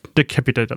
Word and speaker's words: Decapitator: Decapitator? – Decapitator, dankeschön.